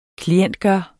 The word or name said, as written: klientgjort